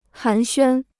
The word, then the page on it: 寒暄: hán xuān